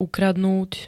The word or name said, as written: ukradnúť